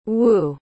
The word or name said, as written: whoo